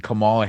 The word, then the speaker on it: Kamala: So what is Joe Biden as president of the United States and Kamala Harris going to have to do to get in trouble after it's Kamala, by the way. Shit. If you say it wrong, that's a no-no.